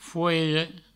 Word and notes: foire: fouire; Provençal

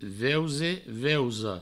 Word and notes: veuse: veusa; veuso

